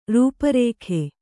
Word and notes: ರೂಪರೇಖೆ: rūpa rēkhe

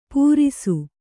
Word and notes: ಪೂರಿಸು: Kannada

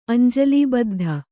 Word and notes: ಅಂಜಲಿಬದ್ಧ: añjalibaddha

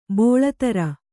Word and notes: ಬೆಳುದುರೆ: beḷudure

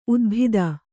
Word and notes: ಊದಬತ್ತಿ: ūdabatti

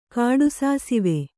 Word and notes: ಕಾಡುಸಾಸಿವೆ: kāḍu sāsive; Kannada

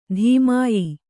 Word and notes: ಧೂಮಾಯ: dhūmāya

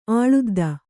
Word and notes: ಆಳುದ್ದ: āḷudda

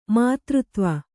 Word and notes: ಮಧುರತ್ವ: madhuratva

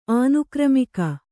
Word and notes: ಆನುಕ್ರಮಿಕ: ānukramika

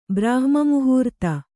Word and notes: ಬ್ರಾಹ್ಮೀಮುಹೂರ್ತ: Kannada

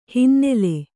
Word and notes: ಹಿನ್ನೆಲ: hinnela

